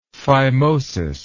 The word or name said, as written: phimosis